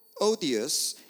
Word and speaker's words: Euodia: And it says, I implore Euodia in chapter 4, verse 2 and 3, and I implore Syntyche, and someone has actually pronounced these a little bit differently. Some pronounced that I implore Odious, and I implore Syntyche to be of the same mind in the Lord.